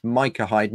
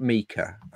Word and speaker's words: Micah Hyde: And he pointed at the team sheet and said, that for Watford is pronounced Micah Hyde. It's Micah Hyde, not Mika.